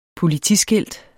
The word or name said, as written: politiskilt